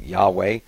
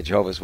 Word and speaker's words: Yahweh: We pronounce it Yahweh. The Jehovah's Witnesses would say Jehovah.